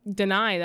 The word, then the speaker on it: Danai: Danai Gurriera. – Is that how you say it? – Danai, that is how you pronounce her first name.